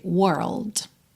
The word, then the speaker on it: world: Pensa sempre em parar primeiro no R, were, depois bota o L, língua lá no céu da boca, whirl, e depois é só fechar com o D, world, tá?